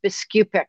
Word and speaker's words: Biskupic: It's Biskupic, like there was a Biskupic.